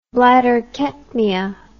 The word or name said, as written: bladder ketmia